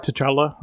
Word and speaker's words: T'Challa: Am I pronouncing it right? – T'Challa. – T'Challa. Okay. – Chadwick T'Challa, Boseman.